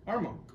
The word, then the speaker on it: Armonk: How you say Armonk? – Armonk. – It was an extra syllable in there, Scott.